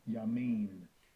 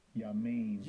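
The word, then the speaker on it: Yamin (ימין): Yamin. Yamin. – Yamin sounds like that slang that we use in the streets. Yamin, you know what I mean?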